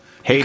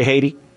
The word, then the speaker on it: Haiti: H-A-I-T-I. – I'm aware how that one is spelled. Say Haiti. Say Haiti. – Haiti. – No, say it the way a normal human says it.